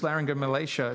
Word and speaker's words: laryngomalacia: So, for example, just to take some of the stories Shelby and I have shared, like the story of Lucas with his laryngomalacia.